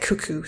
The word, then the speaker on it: Keke: And her name is weird because it's spelled like K-E-K-E, but they pronounce it cuckoo.